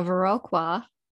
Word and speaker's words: Viroqua: And much of the details of today's case is based on a newspaper clipping from a Viroqua, I hope I said that right this time. – Yep, you did.